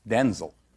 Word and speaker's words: Denzel: Det ska uppenbarligen inte uttalas med E utan med I. Vi kan ju lyssna hur det lät när han var Jimmy Kimmel här. – My name's pronounced Denzel.